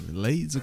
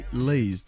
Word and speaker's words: Leys: Pas Lays comme les chips là, mais Lays. Tony Lays.